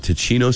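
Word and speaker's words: Tocino: They got, I don't know that I'm saying it right, Ticino seasoning? Spam? – Nailed it.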